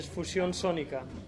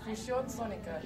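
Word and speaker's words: Fusión Sónica: Okay, in Spanish is Fusion Sonica. – Fusion Sonica? – Yeah. – Am I saying it right? – Fusion Sonica. – Fusion Sonica. – Yeah, that's good.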